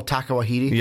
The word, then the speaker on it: Taika Waititi: Oh, Taika Waititi? – Yes.